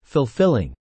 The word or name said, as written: fulfilling